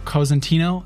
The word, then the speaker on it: Cosentino: Well, I'm very pleased to announce my next guest, Chris Cosentino.